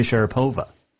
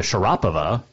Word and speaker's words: Sharapova: Maria just did it first and brought the attention out because it's Maria Sharapova. Or actually, Maria Sharapova is the correct pronunciation.